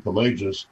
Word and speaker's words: Pelagius: and that's how you say his name, Pelagius, as a heretic because of those views that you brought out.